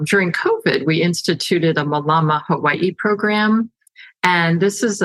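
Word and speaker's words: mālama: It's M A L A M A, and there's a kahako, which is like a looks like a line above the first A, so that tells you, uh, as far as the pronunciation, how to how to say it.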